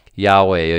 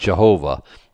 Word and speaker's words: Yahweh: We would say Yahweh or Yahweh, or however you pronounce it. Certainly not Jehovah. Maybe Yahovah. There's no J sound, okay? So Jehovah's, they can witness about anything, but that's certainly not the way it's said.